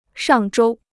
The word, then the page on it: 上周: shàng zhōu